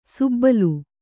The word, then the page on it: ಸುಬ್ಬಲು: Kannada; subbalu